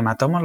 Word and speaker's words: Matomo: Aquí hay alguien que se ha puesto mucho las pilas, que son los de eh, Matomo, ¿lo digo bien? Sí.